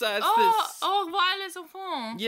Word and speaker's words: Au revoir les enfants: Anyway, your other options for this week that you could have chosen were Au revoir les enfants, I think is how you say that. It's this... – Au revoir les enfants.